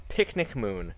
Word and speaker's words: Pxnicmoon: Her name is Napasorn, and you can find her on Tumblr at Picnic Moon.